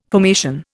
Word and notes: formation: Indian

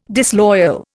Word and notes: disloyal: Indian

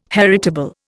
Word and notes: heritable: Indian

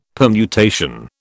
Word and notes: permutation: British English

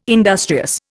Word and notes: industrious: Indian

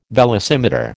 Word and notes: velocimeter: American English

